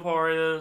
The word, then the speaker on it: Mopar: Mopart. Is that how you say it? Mopart? – Mopar.